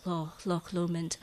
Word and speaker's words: Loch Lomond: You don't pronounce the CH, it's just Loch Lomond.